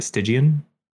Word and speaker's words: Stygian: Stygian? – Yeah.